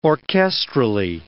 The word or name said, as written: orchestrally